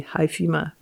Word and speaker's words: hyphema: This study showed that the most serious early injury after bottle cork eye trauma is hyphema. – Spell that. – H-Y-P-H-E-M-A, hyphema, or hyphema, followed by ocular hypertension and corneal edema.